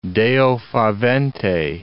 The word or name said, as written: Deo favente